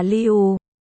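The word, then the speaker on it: liu (六): Số 6 trong tiếng Trung lưu đọc là liu. Gần với cách đọc của từ niu, niu.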